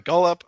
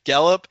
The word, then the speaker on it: Gallup: But without Dak Prescott, without his star receivers like Cooper and Gallup, I think I said his name wrong. – Gallup.